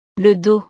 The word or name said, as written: le dos